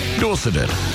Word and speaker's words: Dorseted: with the invented word Dorseted to rhyme with corseted.